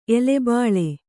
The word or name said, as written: ಎಲೆಬಳ್ಳಿ